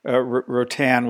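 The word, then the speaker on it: Roatán: Rotan, which most people haven't heard of. It's spelled R-O-A-T-A-N.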